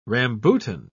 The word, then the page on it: rambutan: British English